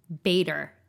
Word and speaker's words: Bader: And so the conversation was between uh, Victoria and uh, I'm trying to, it's Augustinus Bader is how to pronounce it. Augustinus Bader? – Yeah. I've been saying Augustinus Bader. Bader. I know, which sounds more German.